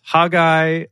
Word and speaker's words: Haggai: So Haggy Waggy, also known as Haggai or Haggai.